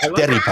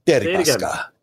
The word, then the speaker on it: Deripaska: Deripaska. I like how you say it. – Deripaska. Deripaska. – Good. That's good. – Yeah. So you've got to roll the R a little bit.